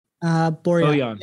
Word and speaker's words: Borjan: And then the second moment was when, is it Borjan? Is that how you say his name? Borjan? – Borjan. Milan Borjan.